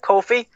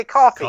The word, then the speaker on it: Kofi: And when they showed Kofi, see that Kofi? I said it right. I didn't say coffee.